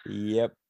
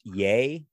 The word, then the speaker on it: Ye: that he he's trying to get his name legally changed to just YE, oh, ah, which I guess is sure. – pronounced Yay. Oh, not Ye, not Yeezy.